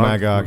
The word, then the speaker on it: Magog: In Kingdom Come, he gets killed by, I don't know how to pronounce his name, Magog? – Oh, Magog, yeah.